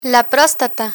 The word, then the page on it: prostata: spanish